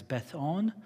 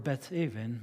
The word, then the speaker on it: Beth Aven: But it's interesting, this Beth Aven, according to scholars, there's two ways of kind of pronouncing it. And I'll have the quote up there. It says, in Hebrew, this name could be vocalized as Beth-on, meaning the house of wealth, or Beth Aven, meaning house of wickedness.